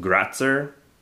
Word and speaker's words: Grätzer: I did notice that there is, like, a preferred German word pronounced Grätzer. Grätzer. So that's a little bit easier to look and see. It's as opposed to Grodzicka.